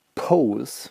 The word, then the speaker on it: Pose: Ich habe auch was mitgebracht und zwar von Layla den Track Pose. Ah, Laila. Mhm. Die Pose wird tatsächlich englisch ausgesprochen.